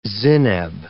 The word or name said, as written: zineb